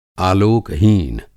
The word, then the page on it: आलेकहीन: Hindi